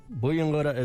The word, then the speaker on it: Buyungurra: simple Mulgana word today is Buyungurra. What is a Buyungurra? Buyungurra is a turtle.